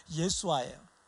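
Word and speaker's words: 예수아 (יֵשׁוּעַ): 여호수아인데 그 여호수아의 여호수아 여호수아 뭐 영어로는 조수아라 그러는데 여호수아라는 이름이 축약형이 뭐냐면 예수아예요. 예수아. 이게 예수아가 원래 우리가 예수라고 하는 것이 원래 발음은요 예수아예요. 예수아. 이게 원래 발음이에요. 우리는 예수라 그러죠. 짧게 이거를 예수아라고 발하는 게 원래 정확한 발음이에요.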